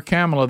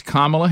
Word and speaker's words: Kamala: By the way, remember early on, stop calling her Kamala. That shows you don't care enough to say her name right. – Mm-hmm. – It's Kamala Harris.